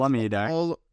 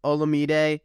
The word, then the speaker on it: Olamide: I believe it's Olamide. – Olamide Zaccheaus. So I got that right.